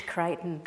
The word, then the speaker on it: Crichton: It's Crichton. – Crichton. – Spelt the English way and pronounced the American way.